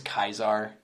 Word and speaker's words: Caesar: With, I get, they pronounce his name as Kaisar in the game, but okay. It's spelt Caesar, so.